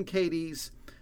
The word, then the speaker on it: Cadiz: Cadiz is the county seat, spelled C-A-D-I-Z, pronounced Cadiz, and it's a small town.